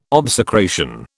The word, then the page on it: obsecration: British English